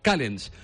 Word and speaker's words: Callens: Ho he dit bé, Callens? – Sí, por lo menos es como lo ha pronunciado el presidente del FI, Geli, por tanto, vamos a respetar a Geli y nombrarle Alexander Callens.